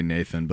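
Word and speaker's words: Neithan: Nathan, not spelled like you would normally say Nathan, but that's just how it's pronounced in Elvish, which means the wronged.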